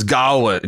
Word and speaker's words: Gawain: Garwin, I always pronounce this Gawain. – I always pronounce it Gawain too, but it's Gawain.